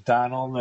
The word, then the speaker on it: Donal: We should have known from your name, Donald. That, yeah. – Is that, we pronouncing that right? – No, not quite, but most Americans can't. Yeah, it's you break it before the end, so it's Donal.